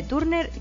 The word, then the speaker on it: Turner: Síndrome de Turner, que en realidad en inglés se pronuncia Turner.